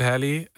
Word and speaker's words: Halle: Chloe X, is het heel of Halle? – Oh, het is Hallie. Geen van beide. Het is Chloe en Halle. – Oh, Chloe en Halle. – Halle, het zijn zusjes.